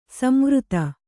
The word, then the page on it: ಸಂವೃತ: samvřta